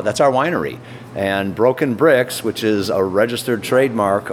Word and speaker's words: Brix: We still have the corporate name Home Brew Shop LTD, but Broken Bricks is the winery name, and it's spelled B-R-I-X.